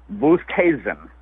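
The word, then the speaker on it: Oosthuizen: He came in and had a little press conference this morning, and I think we finally have settled on Oosterhuizen, is how you say it.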